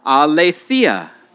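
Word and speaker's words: Aletheia (ἀλήθεια): Aletheia. Aletheia.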